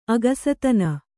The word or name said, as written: ಅಕ್ಕಸತನ